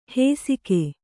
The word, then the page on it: ಹೇಸಿಗೆ: hēsige